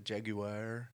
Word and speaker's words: Jaguar: The Jaguar made it, or as the Brits would say, the Jaguar. Ja- jaguar. There's like an extra syllable.